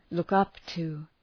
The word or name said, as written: look up to